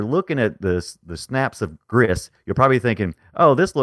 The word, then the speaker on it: Gris: You said it right, Gris, like Chris.